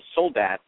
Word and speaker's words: Soldat: His name is, in fact, getting back to the French names, we're changing the pronunciation of his name. It's S-O-L-D-A-T, and I found out that he was named for the French word for soldier, and I've been calling him Soldat, but it's actually Soldat.